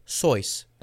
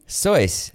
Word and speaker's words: Seuss: Dr. Sooth. – You're pronouncing it wrong. – Dr. Seuss, the popular children's book author's name, is actually pronounced Sois. – Really? – Mm-hmm. – Sois.